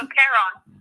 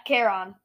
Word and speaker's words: Charon: dollars would work now because, this guy just, Chiron just, Chiron, however you pronounce his name. He just wants... – No, Charon. – Yeah, Charon. He just wants money.